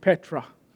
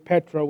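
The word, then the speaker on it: Petra (Πέτρα): The rock, which Peter means in Greek, Petra, would be how you say it in Greek, Petra, which means rock.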